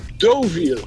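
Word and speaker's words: Deauville: Dan is ik erg me mateloos aan Duviel. Dat ding heet gewoon Doviel.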